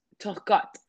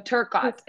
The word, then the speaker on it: Turcotte: So my name should read Ryan, but my my last name is Turcotte. So Turcotte, it's French.